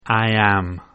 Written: I am

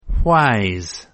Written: why's

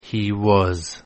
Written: he was

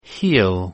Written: he'll

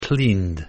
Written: cleaned

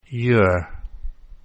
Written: you're

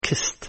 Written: kissed

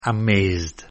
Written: amazed

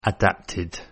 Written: adapted

